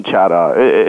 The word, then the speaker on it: Chadha: Chetan Chadha. It's a South Asian name, so sometimes it's hard. – Soft A. I have a long note there, so it's a soft A.